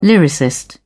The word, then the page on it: lyricist: British English